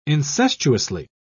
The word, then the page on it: incestuously: British English